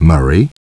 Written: Murray

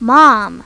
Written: mom

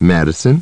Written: Madison

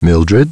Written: Mildred

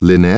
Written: Lynette

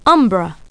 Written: umbra

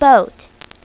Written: boat

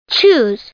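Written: choose

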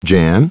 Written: Jan

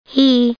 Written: he

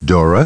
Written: Dora

